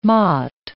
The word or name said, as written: motte